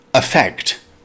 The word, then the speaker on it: affect: Pronounced affect with stress on the second syllable.